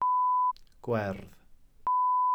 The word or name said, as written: gwerdd